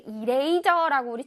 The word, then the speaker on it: eraser: 자, eraser 할때 eraser라고 우리 친구들이 할 텐데, eraser가 절대 아니라 eraser. 자, eray하고 sir를 아주 강렬하게 발음을 해줍니다.